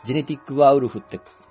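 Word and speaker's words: 汝は人狼なりや: まあ、ジェネティックワーウルフって読むんです。何時は人狼なりやって書いて、ジェネティックワーウルフって読むっていう。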